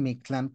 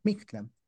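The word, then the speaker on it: Mictlan: Y luego tenemos Mictlán, que no se dice Mictlán, por cierto, no se acentúa la tlán, se acentúa la i, Mictlán.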